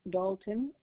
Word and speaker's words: Dalton: Victoria Dalton, and that's spelled B as in dog, A-L-T-O-N.